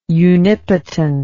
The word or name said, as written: unipotent